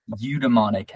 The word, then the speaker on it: eudaimonic: Eudaimonic happiness.